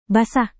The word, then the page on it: basah: indonesian